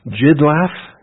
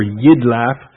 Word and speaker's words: Jidlaf: Jidlaf, or Yidlaf, pronounce it with a Y.